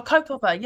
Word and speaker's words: kaupapa: And is it, how do you say, is it kaupapa? Am I saying it right? – A what? – Kaupapa? – Oh, kaupapa, yeah. – Kaupapa, sorry.